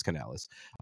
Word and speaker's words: Canales: Is that how you say his last name? – I thought it was Canales. – Canales. I could be wrong. – I said it wrong. It's Canales.